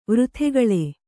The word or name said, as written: ವಾರತೆಕೇಳು